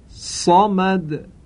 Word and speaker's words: صمد: اما تذکر مهم، دیده شده برخی از نمازگزاران به عنوان مثال وقتی سوره قل هو الله رو میخوانند کلمه صمد رو با سین تلفظ میکنند، یا حداقل وقتی صاد رو تلفظ میکنند صدای سین شنیده میشه.